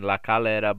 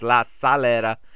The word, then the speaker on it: La Calera: I had been saying La Calera, but it is La Calera.